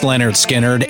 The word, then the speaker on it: Lynyrd Skynyrd: You just don't get any bigger than Lynyrd Skynyrd's pronounced Lynyrd Skynyrd, and at its core, their signature number, Freebird.